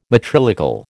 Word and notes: matrilocal: American English

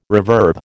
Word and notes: reverb: American English

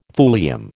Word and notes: thulium: American English

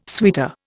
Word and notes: suitor: Indian

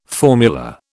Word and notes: formula: British English

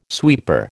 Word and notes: sweeper: American English

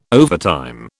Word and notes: overtime: British English

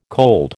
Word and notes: cold: American English

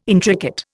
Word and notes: intricate: Indian

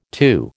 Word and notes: TU: American English

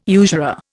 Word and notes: usurer: Indian

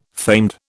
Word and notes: famed: British English

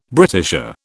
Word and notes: Britisher: British English

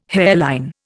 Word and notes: hairline: Indian

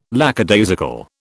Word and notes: lackadaisical: British English

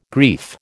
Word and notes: grief: American English

